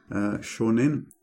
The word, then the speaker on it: Seanan: Seanan.